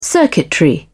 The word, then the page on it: circuitry: British English